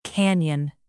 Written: canyon

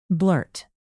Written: blurt

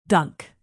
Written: dunk

